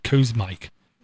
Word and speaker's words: Kosmik: It's called Cosmic Revenge. K-O-S-M-I-K. – Ah, gotcha. Revenge. I kept trying to figure out how to pronounce it. Cosmic.